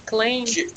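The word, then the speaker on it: Klain: Вот его фамилия Он пишется так. – Клейн. – Клейн, да. – Клейн, Клейн, да. Хотя это, понятно, что от Кляйна идет там.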